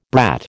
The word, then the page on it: brat: American English